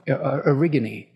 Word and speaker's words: Erigone: is that myth of Erigone.